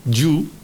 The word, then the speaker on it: you: Para ti sería for you, porque ti o tú se escribe Y-O-U y es I-U. Muy importante, tú no es you, no es con la E, es como el yes de antes, ¿os acordáis? Entonces es you, I-U en vez de you, ¿ok?